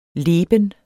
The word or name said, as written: leben